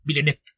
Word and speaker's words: Villeneuve: Y yo lo he comentado antes, yo soy muy fan del original y me encantó lo que hizo Villeneuve, que según yo es la pronunciación rápida y compacta como francés, nada más piensa en saltarte la U ya. Y leerlo rápido, Villeneuve.